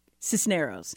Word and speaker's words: Cisneroz: So before the show, I was like, how do you say your last name? And then we say it now? – Cisneroz. – Cisneroz. So I was like, okay, Cisneroz, yes.